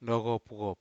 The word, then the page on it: noco puwop: Aneityum